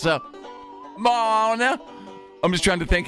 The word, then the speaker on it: Moana: A woman in Georgia recently asked Dairy Queen to make a birthday cake for her 25-year-old daughter, who loves the Disney movie Moana. I hope I said that right. – Moana. – I should. Yes, Moana.